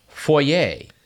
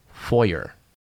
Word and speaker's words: foyer: But another fake French word is foyer. That's not right. It should be pronounced foyer.